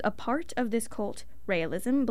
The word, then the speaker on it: Raelism: Raelism. We looked up how to pronounce it, and there were multiple ways to pronounce it, so we're going to stick with Raelism.